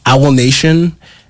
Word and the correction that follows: AWOL Nation: Is that their name? You know what I'm talking about? The guys that made Sail? – Oh, yeah. – AWOL Nation. Yeah. Thank you.